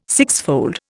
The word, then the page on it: sixfold: Indian